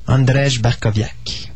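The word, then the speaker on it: Andrzej Bartkowiak: Tu le prononces comme c'est écrit. – Andrzej Barkowiak, voilà.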